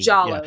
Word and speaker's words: Giallo: Probably the most well-known Giallo movie. Or Giallo. I don't know if that's a hard G or a soft G. – Giallo.